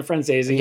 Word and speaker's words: Franzese: Daniel Franzese. Did I say it right? – Yeah, Daniel Franzese.